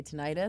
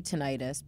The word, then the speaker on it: tinnitus: because some people say tinnitus, it's like tomato, tomato. But the doctor we had on said she doesn't say tinnitus because itis usually means an inflammation and it's not an inflammation.